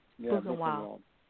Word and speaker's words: Buchenwald: You produced the award-winning documentary feature Kinder Block 66, Return to Buchenwald? Is that how you say it? – Buchenwald, yeah. Buchenwald.